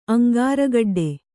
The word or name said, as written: ಅಂಗಾರಗಡ್ಡೆ